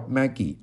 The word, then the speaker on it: Magi: 他说：“ 我们崇拜的是智者，是 Magi 啊，M-A-G-I，不是 Mega。 Mega 是什么呢？Mega 是 Make America Great Again，让美国再次伟大。” 他说：“ 我们我们崇拜的是智者，那个读音叫 Magi，而不是 Mega。这个这个讲法 M-A-G-I。” 而不是 M A G A 啊，这个是一个区别。